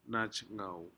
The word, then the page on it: najgau: Aneityum